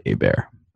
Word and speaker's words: Hebert: So, of course, it's C L A Y, and then the last name is H E B as in boy, E-R-T. It's spelled like Hebert, pronounced like Hebert.